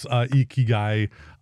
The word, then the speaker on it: Ikigai: For those of you in America, you can pronounce it Iki or Icky Guy. – That's not how you say it. It's like Iki. Like if you're trying to say like Pikachu, it's Ikigai.